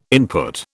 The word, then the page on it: input: British English